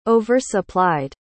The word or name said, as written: oversupplied